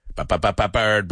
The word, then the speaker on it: bird: The fourth word rhymes with the word word. Rima con la palabra word. Bird, bird, bird. Bird is the word.